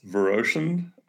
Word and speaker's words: Viroshan: It's Viroshan, and Naicker is right.